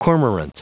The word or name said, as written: cormorant